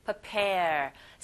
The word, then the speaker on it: prepare: 자, 준비하다 라는 동사 발음하실 때 prepare. 강세가 pair 뒷부분에 있어요. 그래서 이 pre 자체가 빨리 발음하는 경우에는 우리 그 연습 많이 했었죠, 여러분. Prepare. 약간 철자가 뒤집어지는 듯한 PRE인데 소리는 PER처럼 들리는 경우가 있어요. 빨리 발음하면 그렇게 들립니다. Prepare, prepare 이렇게요.